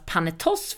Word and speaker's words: Panetoz: de skulle uttalas Panetoss, för det hade vi fått information om och de sjunger Panetoss i Dansa Pausa. – Och frågar Men, man dem själva så säger de lite både och faktiskt.